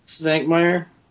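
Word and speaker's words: Švankmajer: Svankmajer.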